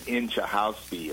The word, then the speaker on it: Inchauspe: How do you pronounce that last name again? – I think it's Inchahousby or something like that.